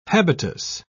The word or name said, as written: habitus